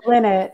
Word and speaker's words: Linnet: And hopefully I pronounced your name right, but now is your chance to correct me. – Dana Linnet, yeah.